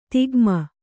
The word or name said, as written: ದಗಮು